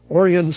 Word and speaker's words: Orion: In the Michigan ARE tip sheet, Larry Russell says, according to the WGN website, Orion Samuelson will be retiring at the end of December. Yes, his name is spelled like the constellation, but pronounced differently. Covers agribusiness, and I remember I used to also see him on TV.